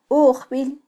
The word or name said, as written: ujbil